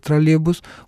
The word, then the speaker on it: троллейбус: Диктор, который является действительно диктором, никогда не скажет троллейбус, он скажет троллейбус. Это полный стиль. А вот в быстром, аллегровом, как его еще называют, стиле произношения, который уместен в разговорной речи, съедаются еще не такие вещи, как вот этот звук «й» в слове «троллейбус».